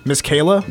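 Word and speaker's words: Masekela: How do you say his last name? I know it as Masakella. Is that right? Hugh Masakella? Is that Miss right? Kayla. – Miss Kayla? – Miss Kayla.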